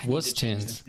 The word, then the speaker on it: Wustones: Let me say this correctly. Wustone Tunes. See, dang it! I kept looking up Wustones on Twitter and going, "Where is this person?" And then I was "Oh, I'm such an idiot." It's Wustones.